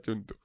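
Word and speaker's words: Cthulhu: aclarado y se pronuncia chuntu. – Se pronuncia chuntu.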